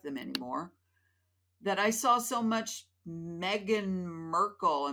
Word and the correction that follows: Markle: Am I even saying it right? And is that her name? Meghan Markle.